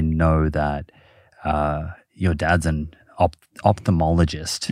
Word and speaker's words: ophthalmologist: You made the error that everyone makes. OPH. – OPH. – Folks, get that right. – Okay. – Ophthalmologist. – Yeah. – Silent H.